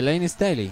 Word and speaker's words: Layne Staley: De Layne Staley.